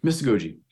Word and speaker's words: mystagogy: Father, on your show, you just recently, one of your last few episodes, you discussed something called mystagogy. Am I pronouncing that right? – Yeah, mystagogy.